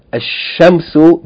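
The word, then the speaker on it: ash-shamsu (الشمس): Note here, ash-shamsu begins with the sun, that is sheen. So the sheen is double, the lam is not pronounced.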